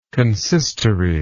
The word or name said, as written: consistorian